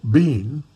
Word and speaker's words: Bean: This mule, Bean, like rhymes with green, spelled with two E's, Bean didn't like too much weight on his back.